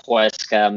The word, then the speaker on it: Huesca: 0-0 ties versus Huesca. Correct me if I'm wrong if I pronounced that horribly. – Yeah, Huesca midweek.